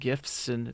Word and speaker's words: GIF: And then it became an art tumbler full of animated GIFs or GIFs or GIFs. – Whatever we want to call wrestling. It. Although the correct term is GIF. It's the hard G.